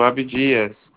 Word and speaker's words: Abdias: Falo com o seu Abadias Batista? – Isso, Abdias. – Abdias, desculpa a pronúncia.